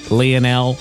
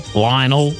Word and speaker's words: Lionel: Yeah, depending on how you pronounce his first name, Lionel Lionel.